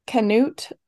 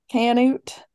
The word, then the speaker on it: Canute: It takes place in Canute, Oklahoma. That's how you say it. Canute, Oklahoma. It's based on, I'm so sorry.